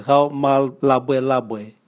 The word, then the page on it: gao mwalabwelabwe: Raga